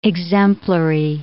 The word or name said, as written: exemplary